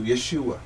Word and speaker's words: Yeshua: The emphasis on the U, Yeshua.